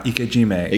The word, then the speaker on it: ikejime: And so this, I don't want to fast forward to the end, but this is the ikejime. Is that saying that right? – Yeah, ikejime.